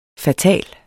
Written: fatal

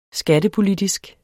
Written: skattepolitisk

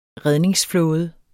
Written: redningsflåde

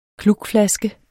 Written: klukflaske

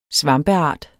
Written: svampeart

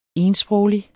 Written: ensproglig

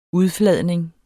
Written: udfladning

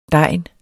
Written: DEGNENE

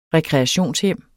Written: REKREATIONSHJEMMET

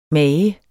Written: mager

